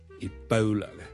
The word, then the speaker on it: Ebola: Ladies and gentlemen, boys and girls, hi, 真故事要跟最近，如果大家有睇国际新闻咧，都应该会留意到 Ebola 呢个伊波拉病毒咧，喺某啲地方肆虐嘅。 Ebola。伊波拉病毒呢，我哋虽然广东话我哋译住伊波拉波啦，我哋系哦一个发音，但如果系英文呢，我哋通常系读 Ebola 嘅 Ebola，煲煲，好似弓箭嘅弓嗰个煲，OK，咁所以呢，唔系 Ebola， but Ebola， Ebola。